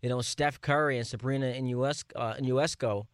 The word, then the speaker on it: Ionescu: Well, first off, let me help you with the pronunciation, because it's a little bit of a tricky one, but it's Sabrina Ionescu. – Ionescu. – Ionescu, it's one of those last names that every time you say it, you get nervous right before you say it.